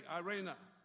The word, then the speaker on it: eirene: It's spelled E-R-E-N-A and pronounced I-R-A-N-A, Irena, which means quietness and rest, which we need sometimes, quietness and rest.